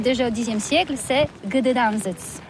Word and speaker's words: Gdańsk: Oui, c'est pas très facile pour des étrangers, mais remarque bien que la langue polonaise, c'est la langue où on prononce chaque lettre qu'on écrit. C'est pourquoi on dit Gdańsk.